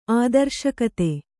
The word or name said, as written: ಆದರ್ಶಕತೆ